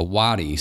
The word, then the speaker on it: wadi: Brook here is literally like a wadi, so I think that's how you say it, W-A-D-I. I'm going to look that up real quick, hold on. Yes, that is the correct pronunciation. I just Googled it, so there.